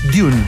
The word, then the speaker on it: Dune: Dune.